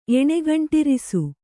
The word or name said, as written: ಎಣೆಗಂಟಿರಿಸು